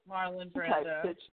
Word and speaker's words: Marlon Brando: Which I think is a very Marlon Brando, I can't pronounce, but Marlon Brando.